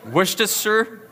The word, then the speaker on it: Worcestershire: Does anybody actually know how to pronounce this kind of sauce? Huh? Worcestershire? Okay. Worcestershire. So it's not Worcestershire, which is what it looks like. Worcestershire, something like that.